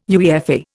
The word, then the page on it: UEFA: Indian